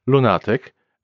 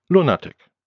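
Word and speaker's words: lunatyk: The only difference being the stress on the second syllable in Polish, lunatic, and the first syllable in English, lunatic.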